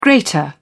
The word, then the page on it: greater: British English